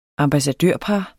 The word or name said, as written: ambassadørpar